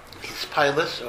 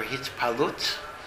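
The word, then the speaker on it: hitpa'alut: Hispailus, or in Hebrew you pronounce it hispalut or hitpalut, is emotional arousal.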